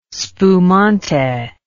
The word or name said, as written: spumante